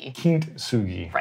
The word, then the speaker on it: Kintsugi: With the T is silent or no? – Kintsugi. So in Japanese, typically you wouldn't say tsu. TSU is pronounced tsu. – Got it. – So very faintly with the T.